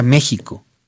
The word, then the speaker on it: México: México se pronuncia México. Y la historia de por qué se pronuncia México es de verdad muy bella, porque tiene que ver con la evolución del sh.